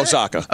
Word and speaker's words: Osaka: Osaki, right? Osaki, is that how you say it? – Osaka. – Osaka. Osaka. She,